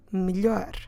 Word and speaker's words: melhor: And that different word in Portuguese is the word melhor. Melhor. Como se escreve melhor? M-E-L-H-O-R.